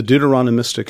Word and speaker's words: Deuteronomistic: The Deuteronomistic history. Deuteronomistic history. You put the emphasis in a different place.